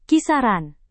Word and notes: kisaran: Indonesian

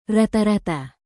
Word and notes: rata-rata: indonesian

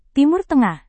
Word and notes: timur tengah: Indonesian

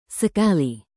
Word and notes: sekali: indonesian

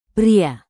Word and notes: pria: Indonesian

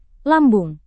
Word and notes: lambung: indonesian

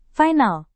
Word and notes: final: indonesian

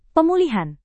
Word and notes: pemulihan: Indonesian

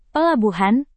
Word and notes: pelabuhan: Indonesian

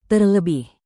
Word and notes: terlebih: indonesian

